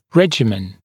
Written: regimen

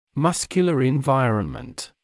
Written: muscular environment

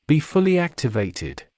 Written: be fully activated

